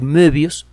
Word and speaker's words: Möbius: Bueno, no si utiliza una hoja de papel normal, pero si usted deforma esa hoja de papel para convertirla en una banda de Mobius, de Möbius, sería la pronunciación, se escribe Mobius con diéresis en la O, entonces la cosa cambia.